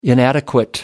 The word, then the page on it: inadequate: American English